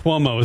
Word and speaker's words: Cuomo: The only thing that rhymes with Cuomo is homo.